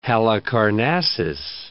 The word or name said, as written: Halicarnassus